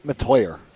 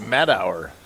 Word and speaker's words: Matteuer: It's spelled M-A-T-T-E-U-E-R. – So I would say Matauer. – It is Matoyer.